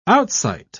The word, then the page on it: outsight: British English